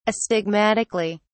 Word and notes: astigmatically: British English

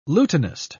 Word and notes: lutenist: British English

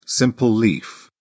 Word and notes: simple leaf: British English

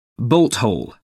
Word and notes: bolt hole: American English

